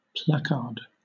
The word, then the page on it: placard: British English